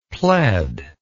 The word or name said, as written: pled